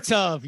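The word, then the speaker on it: Gmar Tov: Yeah, Gamar Tov. Did I say that right? – Gamar Tov, yes.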